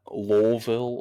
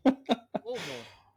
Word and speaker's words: Louisville: l-e-w-i-s um, which makes a little more sense but um, yeah, whenever whenever people say that, I'm like, no, Louisville.